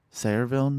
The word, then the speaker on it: Sayreville: You're born in Orange, New Jersey, raised in Sayreville, New Jersey? – Correct.